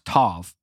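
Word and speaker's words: tov (טוב): The word good and evil in that verse I just read, together, it's the Hebrew word lema, L-E-M-M-A. Good is a word, I'm Hebrew, I don't know. It's called T-O-B, but it's pronounced tov, mm-hmm.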